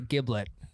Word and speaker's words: giblet: So a gizzard would be considered a giblet or a giblet. – Giblet or a giblet? – He's giblet. – It's a soft G.